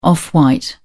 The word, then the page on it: off white: British English